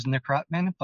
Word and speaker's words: Nekrutman: The correct pronunciation is Nekrutman, but you can just call me David.